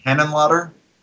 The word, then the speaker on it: Henenlotter: Then you should have loved Donald Trump's Henrotter. No, I didn't like that. – Why do you like Donald Trump's head cuddle? – How do you say his name? – Frank Henenlotter.